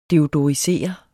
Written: deodorisere

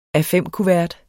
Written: A5-kuvert

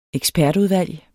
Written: ekspertudvalg

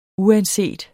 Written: uanset

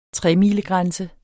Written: tremilegrænse

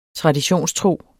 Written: traditionstro

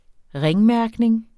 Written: ringmærkning

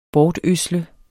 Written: bortødsle

